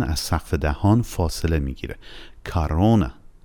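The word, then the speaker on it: کارونا (корона): شاید تعجب بکنید که من چرا کارونا تلفظ کردم، در حالی که بعد از حرف کا توی تصویر او میبینید. یه او داریم که صدای آ تلفظ شده و یه او دیگه داریم، دومین او، که صدای او تلفظ شده. علتش هم این هستش که استرس اودارنیه یا فشار روی حرف روی او دوم قرار داره.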